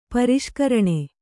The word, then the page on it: ಪರಿಷ್ಕರಣ: pariṣkaraṇa